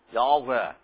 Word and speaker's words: Yahweh: Yah, Yahweh is the name. It's not Y-Schwa. It's Y and this long A sound, Ah.